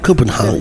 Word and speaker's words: Copenhagen: Copenhagen.